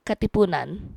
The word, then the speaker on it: Katipunan: I may have typed a P instead of a T. The Katipunan. – Yes. – Am I saying that right? – Katipunan. – Yeah.